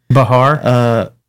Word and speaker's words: Bihar: How do you say this? Bihar, India? – Bihar? – Yeah. – Yes. – Sounds better.